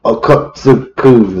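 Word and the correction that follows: Okkotsu: is that how you say it? – So something like that. – Sure. – No, just Okatsu.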